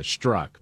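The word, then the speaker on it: Strzok: So Peter Stroke is on with Joe Scarborough. Morning, Joe. – Peter Stroke. I think he prefers the pronunciation Struck, but I'm fine with Peter Stroke, personally.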